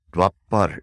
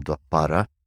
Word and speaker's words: Dwapara: Next, we have Dwapar Yuga. Dwapar is spelled D-W-A-R-A. Dwapar. The A on the end is optional. Dwapara is Dwapar. You drop the A on the end in most Sanskrit words.